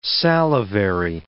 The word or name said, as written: salivary